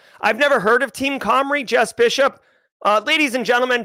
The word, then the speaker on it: Cymru: CYMRU is pronounced Comrie.